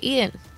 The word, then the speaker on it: Eaton: Well, that's how you say it, Eaton.